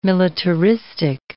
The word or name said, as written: militaristic